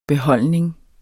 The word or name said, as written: beholdning